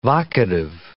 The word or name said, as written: vocative